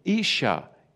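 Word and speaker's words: Ish (איש): And wife is spelt Aleph Sheen Hey Isha.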